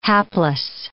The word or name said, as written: hapless